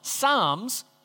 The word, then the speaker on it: Psalms: Yes, the P is silent, Psalms, and it's a book that at its heart is actually designed to be full of songs.